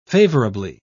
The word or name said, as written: favorably